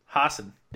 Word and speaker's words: Hassan: Because even like Hassan Minhaj, is that how you pronounce it? – Hassan. – Yeah, that's right.